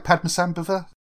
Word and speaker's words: Padmasambhava: Did I pronounce that right, Padmasambhava? – You have indeed.